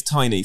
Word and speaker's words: Tiiny: And you're going to have to tell me how to pronounce DJ Tiiny. Is it Tiiny or Tiny? – It's Tiny. So Tiny used to be, he's famous for, he's famous for being a good DJ, but also being Stormzy's tour DJ.